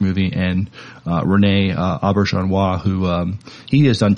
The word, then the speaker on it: Auberjonois: Uh, Auberjonois.